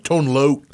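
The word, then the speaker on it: Tone Lōc: So, the night before, if you're looking for a concert to go to, Tone Lock, am I saying that right? Tone Loke. Tone Loke. Oh, Lord. Excuse me. Excuse me. Sir. I've always said Tone Lock for some reason.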